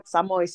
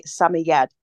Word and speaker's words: Samoyed: Many people say yes, Samoyed, but according to the Samoyed Parent Club of America, you should say Samoyed. I'm going to try and remember that for future podcasts. We'll have to see how far we get with an emphasis on the last syllable.